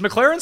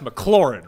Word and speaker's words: McLaren: Is McLaren still a team in F1? – I believe so. – So they know that it's not pronounced McLaurin, right? – Yes.